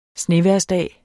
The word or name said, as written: snevejrsdag